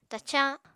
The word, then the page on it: tacañ: Apatani